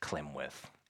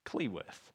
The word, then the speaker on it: Cleworth: A friend actually the other day sent me like a little a photo of like an old yearbook, and my name was Clemworth in the yearbook, which is not my name. My name is Cleworth.